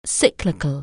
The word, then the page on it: cyclic(al): anglais